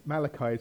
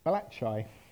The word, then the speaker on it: Malachi: But your love for God and others might be small compared to someone who thinks Malachi is pronounced Balachai, as I once encountered.